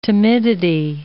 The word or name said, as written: timidity